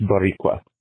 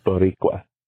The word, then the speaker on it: Boricua: It's like 5 a.m., and it took me forever to realize what John meant when he was saying Boricua. It's pronounced Boricua. – What? – Boricua. That's what Puerto Ricans call themselves. The island is called Borican. The people are called Boricua.